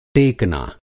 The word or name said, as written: टेकना